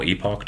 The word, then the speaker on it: epoch: in Cardano, we have an epic or epoch, depending the pronunciation, depending on where you are in the world.